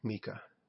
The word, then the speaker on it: Micah: And Mephibosheth had a young son whose name was Micah. or Mika. Let's see how that's pronounced. There it is in verse 12. Okay, Mika.